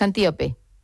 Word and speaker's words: Antíope: ahondas mucho en la relación de Teseo con Antílope, pero me gustaría, lo he dicho mal el nombre, es Antíope.